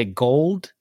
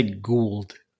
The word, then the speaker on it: Goa'uld: I'm always I either say gold or Guauld. I can't just I can't just say Goa'uld.